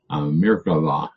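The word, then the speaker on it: Merkavah: But when we go to Ezekiel, in Jewish literature, they're going to talk about the mikra. And what that is a word for chariot. It comes from a root, or mirkava. Pardon me, I said it wrong. Mirkava, I'm thinking of another thing I have on my desk.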